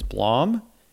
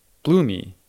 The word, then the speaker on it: Blom: Like, it's that's a or I had another friend who his, everyone would mispronounce his last name is Blom, and he would always cor- correct them. And he's like, "No, it's Bloomy,"